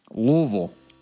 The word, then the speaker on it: Louisville: Louisville. – He's saying it right. – He's saying it right, and we're very proud of you. – It's the only way to say it. – Louisville. – Just like you have a couple nuts in your mouth, and it comes out.